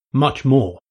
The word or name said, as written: much more